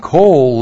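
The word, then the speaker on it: coal (קול): And with it we have coal, Q long O L, coal. And coal literally means sound or a voice.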